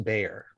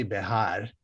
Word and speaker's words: Bexar: There's a county in Texas, the county that San Antonio is in, which is spelled B-E-X-A-R. – Yeah. – Okay. – But it's pronounced Bayer. – Oh, wow. Okay. – That's cool. But it's actually Behar, but then the whiteified version of that is Bayer.